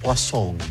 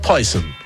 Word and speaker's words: Poison: y la ciútica de la vendedora lo pronunciaba Poisson. Poisson, no Poison.